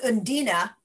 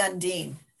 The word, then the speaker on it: Undine: And so the other one that was an international film was Undine. Is that correct? – They actually pronounce it Undina, but okay. I would say Undine.